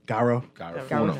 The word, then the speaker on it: Garifuna: It was just different from the, pronounce that G word again, Garifuna.